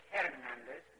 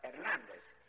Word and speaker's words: Hernandez: By the way, the H in Spanish is silent. So you don't say Hernandez, but Hernandez.